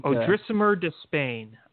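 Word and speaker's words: Odrisamer Despaigne: Odrisimer, I think. – Odrisimer de Spain, I think is how you say it. – Yes.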